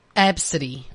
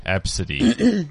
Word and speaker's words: Abcde: This is the kid's name, but it's pronounced A B City. – Absidy. – Absidy.